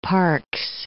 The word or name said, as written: Parkes